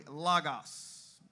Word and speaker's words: logos: That does not say logos. This is a word that is pronounced this way, logos. Everybody say logos.